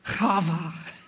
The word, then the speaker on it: Chava: I looked it up, and it's pronounced Chava.